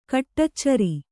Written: ಕಡೆಚೋರಿ

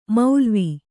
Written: ಮೌಲ್ವಿ

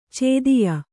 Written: ಚೋದ್ಯ

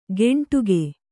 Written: ಗೆಂಟುಗೆ

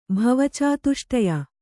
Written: ಭವಚಾತುಷ್ಟಯ

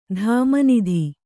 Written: ಧಾಮನಿಧಿ

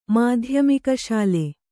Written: ಮಾಧ್ಯಮಿಕಶಾಲೆ

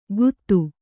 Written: ಗುತ್ತು

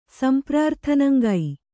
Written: ಸಂಪ್ರಾರ್ಥನಂಗೈ

